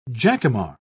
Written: jacamar

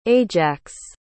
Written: ajax

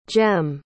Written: Jem

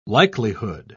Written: likelihood

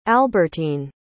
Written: albertyne

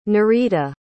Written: Nerita

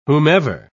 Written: whomever

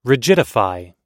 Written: rigidify

